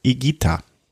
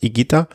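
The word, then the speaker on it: Higuita: Also das H ist stimmlos und das U ist stimmlos. Ähm, hat mir mein kolumbianischer Kollege erklärt. Ähm, und der, er hat dann auch noch den, die Bonusinformation gebracht. Ähm, das ist, da gibt's gibt's einen Fußballer, Fußballtorhüter, der dann auch immer falsch ausgesprochen wird, weil die sprechen, die meisten Reporter sprechen den falsch auf. Also das heißt Igita, also stimmloses H und stimmloses U.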